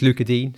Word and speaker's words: Digne: And yeah, I mean, apparently it's Luca Dean. So I thought it was Dinia. And then I heard Fabrizio Romano on a pod on YouTube earlier today. He pronounced it like that. And then I did a bit of research and I found an old Everton TV clip where Luca Dean says that apparently that's how you pronounce his name.